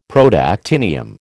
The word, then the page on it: Protactinium: American English